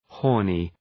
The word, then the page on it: horny: ‘hɔ:rnı